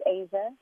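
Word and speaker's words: Aja: Asia, like the continent. – Oh, Asia. Okay, it's not spelled like Asia on my screen. It's spelled differently than that. Okay, go ahead. It's spelled like A-S-I-A? – It's A-J-A, like Steely Dan.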